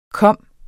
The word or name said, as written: komme